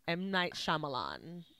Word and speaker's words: Shyamalan: his last name like that, but it's hard to say. M. Night Shyamalan. – Yeah, you said that right.